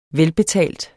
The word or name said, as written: velbetalt